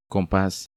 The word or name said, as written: Compas